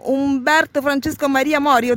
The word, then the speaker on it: Umberto Francesco Maria Mori: Buongiorno Umberto Francesco Maria Mori, ho detto giusto? – Hai detto giustissimo, grazie.